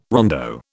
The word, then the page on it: rondo: British English